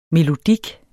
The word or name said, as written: melodik